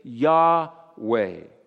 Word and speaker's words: Yahweh: Yahweh.